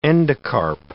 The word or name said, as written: endocarp